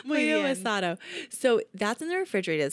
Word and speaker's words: pollo asado: Their pollo asado. Is that how you say it? – Yeah. – Pollo asada? Wait, what is it? – Pollo asado.